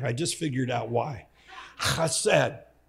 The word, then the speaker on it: chesed: It's C-H-E-S-E-D is how you would pronounce it, write it if you're translating from Hebrew. It's chesed.